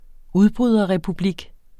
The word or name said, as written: udbryderrepublik